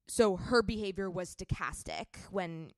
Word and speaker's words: stochastic: Stochastic.